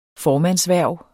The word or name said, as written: formandshverv